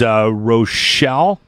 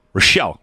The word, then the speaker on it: Roshel: The company is Rochelle. R-O-S-H-E-L. Rochelle, yes. I'm pretty sure I'm saying that properly.